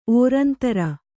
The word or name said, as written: ಓರಂತರ